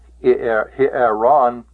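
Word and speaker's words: Hieron (ἱερόν): Hieron, notice that's a rough breathing mark there. Hieron. And the epsilon in this word is a syllable by itself. Ron is the ultima. Is the penult, and he is the antepenult.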